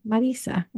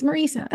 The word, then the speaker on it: Marisa: And I like how you say my name, Marisa. That's how my Italian family says it.